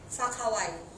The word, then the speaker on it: Whakawai: It's called Fuck Hawaii, but it's spelled as WHAKAWAI.